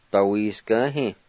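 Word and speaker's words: dawisgvhi: Number three, slippery, dawiskahe.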